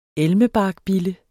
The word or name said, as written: elmebarkbille